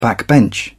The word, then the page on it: backbench: British English